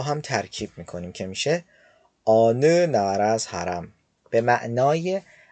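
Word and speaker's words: آنو (어느): خب کلمه بعدی ما کلمه آنوه. آنو به معنی کدامه. دقت کنید که این کلمه آنی تلفظ نمیشه، بلکه آنو تلفظ میشه.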